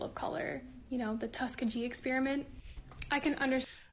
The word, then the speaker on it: Tuskegee: Again, if you're gonna sound like you know what you're talking about, you should at least be able to correctly pronounce the name of the experiment. Tuskegee, not Tuskegee.